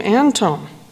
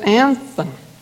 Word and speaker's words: Anthon: Why do you pronounce Antone H. Lund's name Antone? My grandmother, who knew many general authorities, pronounced it Anthon.